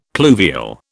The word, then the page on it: pluvial: British English